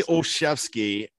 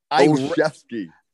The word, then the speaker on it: Olszewski: it's officially Olszewski. – Olszewski.